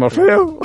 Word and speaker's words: Morfeu: és, la pronunciació, és la pronunciació correcta de Morfeu, la que has fet. Morfeu! Morfeu! – amb, amb l'A oberta, correcte.